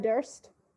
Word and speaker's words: Durst: Yeah, it's basically German for thirst. So if you were to say I'm thirsty in German, you would say Ich habe Durst.